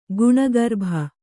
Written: ಗುಣಗರ್ಭ

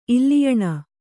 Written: ಇಲ್ಲಿಯಣ

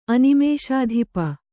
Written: ಅನಿಮೇಷಾಧಿಪ

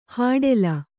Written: ಹೊಟೇಲು

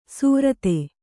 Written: ಸ್ವರಿತ

